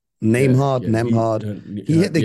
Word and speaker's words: Nembhard: Yeah, this kid Nemhard. Nembhard. Is that how you pronounce it? Yeah, yeah, yeah.